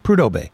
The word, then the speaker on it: Prudhoe Bay: Did I say Depot Bay? Prudhoe Bay.